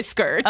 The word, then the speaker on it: Skurt: S-K-U-R-T. It's Skirt.